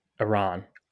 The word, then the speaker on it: Iran: Iran.